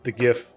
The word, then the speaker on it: GIF: The GIF is still good. You see he's showing 12. And... – I think it's pronounced JIF.